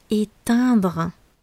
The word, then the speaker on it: éteindre: Ne confondez pas avec le verbe éteindre, qui est très proche au niveau de la prononciation. Éteindre, éteindre la lumière, le contraire d'allumer. Ici, on parle du verbe étendre. Est-ce que vous entendez bien la différence Étendre, j'étends le linge. Éteindre, j'éteins la lumière.